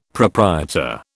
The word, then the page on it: proprietor: British English